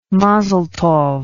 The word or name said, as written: mazal tov